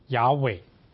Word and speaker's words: 雅伟: 他告诉摩西他的名字是耶和华，或者按照原来的文字正确的发音呢，要读成雅伟。